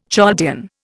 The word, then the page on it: Chaldean: Indian